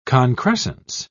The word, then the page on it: concrescence: British English